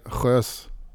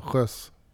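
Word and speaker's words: Geuze: Men på flamländska blir det mer sjös. Lite, lite så, sjös.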